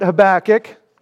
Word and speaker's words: Habakkuk: If you would open up your Bible to the book of Habakkuk, and that's how we will pronounce it, Habakkuk.